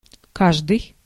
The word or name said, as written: everybody